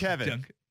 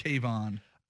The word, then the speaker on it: Kevon: It's Kevin.